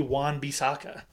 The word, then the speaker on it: Wan-Bissaka: Does it rhyme product. – With Schmon Schmishaka? – That was a terrible way to do that. But yes, it does rhyme with Schmon Schmishaka. It would be Wan Bissaka.